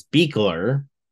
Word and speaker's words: Buechler: His last name is hard to pronounce. It's Buechler, if you can get it out Buechler.